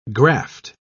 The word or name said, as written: graft